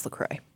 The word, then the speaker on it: LaCroix: LaCroix. It is. It's LaCroix. It's pronounced LaCroix.